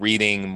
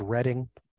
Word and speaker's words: Reading: And you don't always have diagrams for like those old reading mortise locks that are out there, or Tim, it's pronounced Redding.